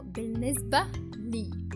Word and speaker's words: بالنسبة لي: بالنسبة لي it's two words but we pronounce it as one word.